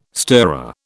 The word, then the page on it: stirrer: British English